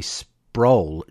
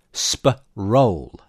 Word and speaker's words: Sproul: who said it is sp-roll, like a dinner roll. And during my only trip to England, the English had no problem pronouncing the roll. Justin pronounces it sproul, like a hoot owl.